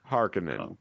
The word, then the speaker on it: Harkonnen: Harkonnen, that's how you say it.